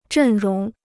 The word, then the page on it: 阵容: zhèn róng